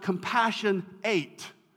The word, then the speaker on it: compassionate: In fact, originally that word wasn't even pronounced compassionate. It was actually pronounced compassion eight.